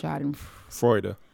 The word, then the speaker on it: Schadenfreude: I know what it means, but I pronounced it Schadenfreude forever until mm-hmm. 2 days ago. – Mm-hmm. – And you were like, nah, it's pronounced Schadenfreude. Schadenfreude. – The E is not silent.